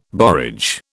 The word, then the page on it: borage: British English